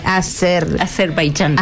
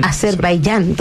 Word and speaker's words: Azerbaiyán: Azerbaiyán. – Azerbaiyán.